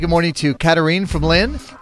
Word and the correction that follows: Katharine: It's Katharine, but that's okay. – Katharine. Okay, Katharine. I want to get it right. As somebody whose name is spelled weirdly, I get very upset when people get it wrong. Katharine. Now I got it.